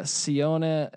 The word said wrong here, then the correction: Sione: or I don't know how to pronounce these names. Finau. – Yeah, that's Sione.